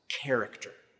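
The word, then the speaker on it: character (χαρακτήρ): The Greek word there for exact representation is the Greek word that is pronounced character.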